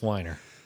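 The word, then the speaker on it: Weiner: It sounds like a German name, and in the German names, you pronounce the second vowel, not the first one. – So it's Wiener? – It's Wiener.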